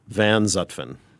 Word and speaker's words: Van Zutphen: Van Zutphen.